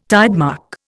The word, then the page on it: tidemark: Indian